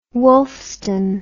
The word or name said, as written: wulfstan